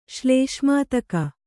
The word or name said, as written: ಶ್ಲೇಷ್ಮಾತಕ